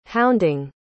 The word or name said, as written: hounding